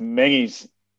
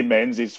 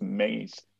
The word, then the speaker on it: Menzies: So my surname is pronounced Mengies. I just say Menzies for people who are not aware that it's pronounced Mengies.